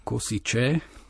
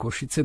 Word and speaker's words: Košice: lebo Košice, to pre celý svet to boli Kosiče, tak ja som ich naučil, že Košice sú Košice,